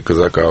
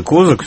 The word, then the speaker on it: Козак: И пресс-секретарь Дмитрия Казака, значит, он сказал, обратился к журналистам, что ударение надо делать все-таки на второй слог «Казак». Так, хорошо. – Нет, ну я привык к тому, что это Козак все-таки, да.